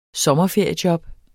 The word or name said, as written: sommerferiejob